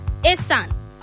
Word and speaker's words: Esan: And the one we're doing today, is Esan. It's spelled E-S-A-N, Esan.